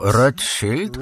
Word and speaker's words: Rothschild: Wymawiając bardziej po niemiecku Rothschild, a w tłumaczeniu Czerwona Tarcza.